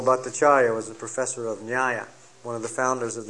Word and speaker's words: Nyaya: N-Y-A-N-A, long A, Nyaya.